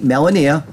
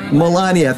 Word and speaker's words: Melania: In Orlando on June 18th, I will be announcing my second term presidential run with First Lady Melania. Is that how you say it? – No. – Melania. Thank you. I knew I did that wrong.